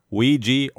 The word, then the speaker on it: Ouija: That word, spelled O-U-I-J-A, can be pronounced either Ouija or Ouija.